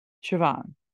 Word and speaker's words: Siobhan: And people can be touchy on that and Siobhan, okay, Siobhan, which I love the spelling of it.